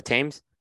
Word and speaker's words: Thames: And I think one of the great moments I've had was smoking a cigar on the Thames, right? Am I saying it right? – The Thames.